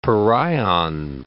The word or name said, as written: pereion